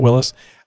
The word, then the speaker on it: Willys: He corrected me on how to pronounce that. It's like, what you talking about, Willys?